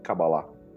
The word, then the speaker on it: Cabala: E esse algo é justamente o tema da nossa discussão de hoje, que é a Cabala, ou Cabalá, como, como conhecedores falam, né? pronunciam, pronunciam o nome.